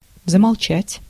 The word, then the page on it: замолчать: [zəmɐɫˈtɕætʲ]